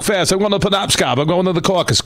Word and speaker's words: Penobscot: They're in the – is it – how do you pronounce the building, Marty? – Penobscot. – Yeah. They're in the Penobscot.